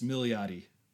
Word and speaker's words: Milioti: I brought her up a few times, but I think this is the first time I actually looked up how to pronounce her name. I'm pretty sure I've been pronouncing it wrong the whole time. It's Milioti.